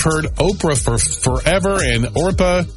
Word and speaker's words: Orpah: It was Orpah. O-R-P-A-H. It's the Bible story of Ruth. That is a name in that story, but relatives kept mispronouncing it to Oprah, and apparently that's what she went with.